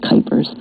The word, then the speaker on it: Kuipers: Kuipers, Cupers, Kippers, Cuppers, and so I was like, no, Kuipers. Rhymes with hyper.